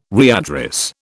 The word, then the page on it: readdress: British English